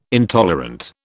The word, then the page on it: intolerant: British English